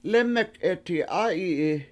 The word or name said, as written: lEmek7eti7A7yi7i